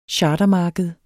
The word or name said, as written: chartermarked